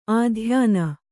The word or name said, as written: ಆದ್ಯೂನ